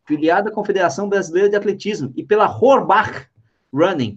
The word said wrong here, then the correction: Horbach: Primeira coisa, corrige, corrige minha pronúncia aí, cara. É Orbach. Orbach.